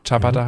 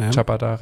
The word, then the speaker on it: Ciabatta: Ciabatta heißt es ja, Entschuldigung. Ciabatta, richtig ausgesprochen italienisch.